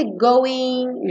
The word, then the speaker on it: goin': Então, eu coloquei novamente aqui escrito para quem baixar o PDF é, ter essa anotação escrita para não esquecer que esse going, G-O-I-N apóstrofo, é uma redução do going, G-O-I-N-G, que é a maneira correta de se escrever, mas que é muito comum em música, letra de música, para já te indicar que esse G não é pronunciado, ok?